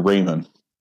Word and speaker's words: Raymond: Who is it Raman or Raymond? – Raymond, spelled like Raman. – That's right. Raymond spelled like Raman. That's why I keep saying Raman.